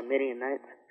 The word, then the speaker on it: Midianites: But it says that the, how do you pronounce with the M? – Midianites? – Yeah, Midianites.